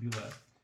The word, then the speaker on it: Bulette: Bulette. I know you guys say Bulette, but no, that's the French pronunciation. It's a Bulette.